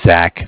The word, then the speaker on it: Zach: My name is Zach, spelled Z-A-C-H.